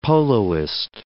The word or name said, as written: poloist